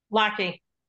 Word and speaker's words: Laque: Laque.